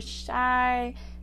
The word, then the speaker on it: Chey: friends, and welcome back to another episode of Coffee with Chey, or Chey, however you pronounce it.